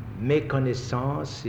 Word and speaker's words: méconnaissance: Méconnaissance is a French word which I am obliged to pronounce in French, to spell in French.